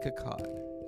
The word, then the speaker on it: Akkad: Akut. Following the release of Ends. What is his name? Let me say it properly. Malik Akkad.